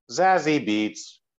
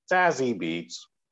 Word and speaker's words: Zazie Beetz: Also starring Brian Tyree Henry, Lakeith Stanfield, and Zazie Beetz. That's how you have to say her name. – Or at least Zazie Beetz. – Zazie Beetz.